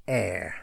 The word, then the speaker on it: heir: So the letter H is always silent in words like heir, the heir to the throne, H-E-I-R, silent H. Let's be honest, honest, he is an honest man, H-O-N-E-S-T.